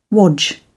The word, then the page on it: wodge: British English